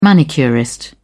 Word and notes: manicurist: British English

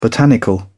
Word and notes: botanical: British English

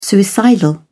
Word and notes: suicidal: British English

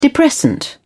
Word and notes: depressant: British English

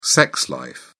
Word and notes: sex life: British English

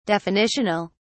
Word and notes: definitional: British English

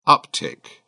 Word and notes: uptick: British English

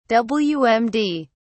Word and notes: wmd: British English

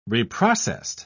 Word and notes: reprocessed: British English

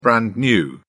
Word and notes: brand new: British English

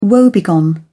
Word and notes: woebegone: British English

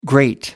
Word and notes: great: American English

